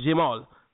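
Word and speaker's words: Jamaal: You know, I, I spell my name Jamal with two A's, and most people don't spell J- Jamal with two A's. They spell it with one. That's why they pronounce Jamal. Mine isn't pronounced like that. It's pronounced Jamal.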